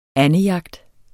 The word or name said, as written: andejagt